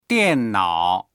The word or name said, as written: dian4nao3